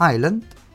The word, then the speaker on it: island: في ايلاند ايلاند جزيرة انتبهوا i s l a n d تنطق ايلاند.